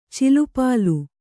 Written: ಚಲ್ಲಪಿಲ್ಲಿ

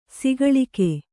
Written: ಸಕಾಲಿಕ

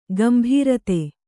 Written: ಗುಂಬಾರತಿ

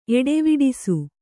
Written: ಎಡೆವಿಡಿಸು

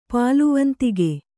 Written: ಪಾಲುವಂತಿಗೆ

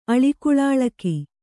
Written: ಅಳಿಕುಳಾಳಕಿ

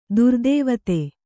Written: ದುರ್ದೇವತೆ

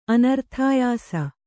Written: ಅನರ್ಥಾಯಾಸ

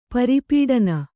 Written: ಪರಿಪಠನ

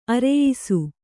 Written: ಅರೆಯಿಸು

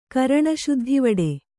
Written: ಕರಣಶುದ್ಧಿವಡೆ

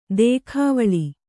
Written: ದೇಖಾವಳಿ

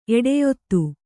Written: ಎಡೆಯೊತ್ತು

